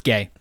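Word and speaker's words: Gueye: And when you have Muhammad Gouai, is that how you pronounce his last name? – I think, is it Gay here? – It's Gay. Muhammad Gay.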